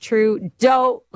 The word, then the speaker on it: Trudeau: My bug, as usual, is the federal liberals and Trudeau, pronounced Trudeau, like Homer Simpson.